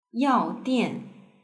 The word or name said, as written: yaodian